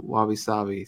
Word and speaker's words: wabi-sabi: And and I don't want to butcher the name, the wabi-sabi. – Yep, you got it just right.